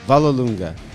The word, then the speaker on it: Vollelunga: Vollelunga.